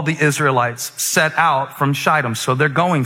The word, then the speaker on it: Shittim: It says, early in the morning, Joshua and all the Israelites set out from Shittim, that's a long I.